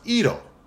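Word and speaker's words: iro (色): Japanese, iro, is pronounced iro and means color.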